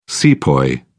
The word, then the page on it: sepoy: British English